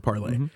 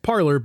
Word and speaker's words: Parler: Parler, if you don't know what it is, it's spelled like parlay, the French word parlay. – Mm-hmm. Actually, isn't that P-A-R-L-E-Z? – No. It's spelled like parlay. – P-A-R-L-E-R, yeah. – But it's pronounced parlor because they're a bunch of idiots.